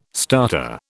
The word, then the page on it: starter: British English